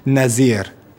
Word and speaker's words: Nazeer: K-H-A-L-I-D. – Thanks. And what's your surname, please? – Nazir. – Okay, and how do you spell that, please? – N-A-Z-E-E-R. – N-A-Z-E-E-R.